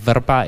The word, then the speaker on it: Vrba: Also ja, Jakub schreibt man mit K und U, und Verba ist halt V-R-B-A.